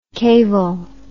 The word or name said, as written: cavel